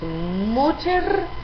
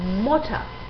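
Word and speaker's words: Mutter: Por ejemplo, no decimos MOCHER, sino MOCHA. No decimos TASTATUR, sino TASTATUA. Abrimos la última vocal.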